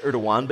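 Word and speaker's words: Erdoğan: It's pronounced Erdogan, but yes, Erdogan.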